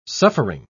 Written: suffering

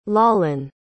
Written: Lalan

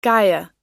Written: Gaia

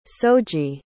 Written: soji

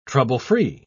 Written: trouble-free

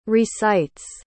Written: recites

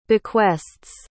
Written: bequest's

